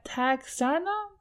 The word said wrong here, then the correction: Texarkana: Texerkana. Pronunciation. Texarkana. There we go.